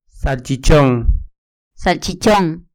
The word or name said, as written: salchichon